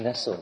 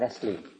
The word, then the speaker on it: Nestle: Not pronounced Nestle, but Nestle.